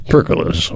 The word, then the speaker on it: pergola: But we're going to talk first about pergolas, or some people say pergolas, or whatever they pergola is how you say it,